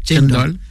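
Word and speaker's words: cendol: Alors là, un plat que je ne connais pas du tout, c'est le chendol, je prononce bien? – Oui, c'est ça, chendol.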